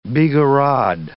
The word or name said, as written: bigarade